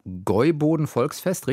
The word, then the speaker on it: Gäuboden-Volksfest: Hier steht Besuch auf dem Gäuboden-Volksfest, richtig ausgesprochen? – Ja, das ist auch gleich mein Favorite.